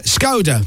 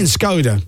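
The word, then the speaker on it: Škoda: They even have to tell us how to say it because we're like, Skoda. They're like, no. Czech Republic. – No. – Skoda. Skoda. – Skoda. – No, listen to me. Skoda. I'm saying Skoda.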